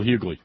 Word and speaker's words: Hughley: Is it pronounced Hughley? D.L. Hughley? Hughley? – D.L. Hughley, yeah.